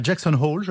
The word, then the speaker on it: Jackson Hole: Ce matin, vous nous emmenez à Jackson Hole, je prononce correctement ? – Très bien.